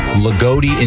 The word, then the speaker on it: Loogootee: L-O-O-G-O-O-T-E-E. – Lagodi? – Lagodi, Indiana.